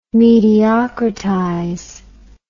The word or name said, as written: mediocritize